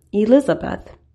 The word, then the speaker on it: Elizabeth: It's pronounced as E, as in the fifth letter in the alphabet, Liz, as in lizard, A, as in the first letter in the alphabet, and Beth, as in the wise woman in the Bible. Altogether, we have Elizabeth.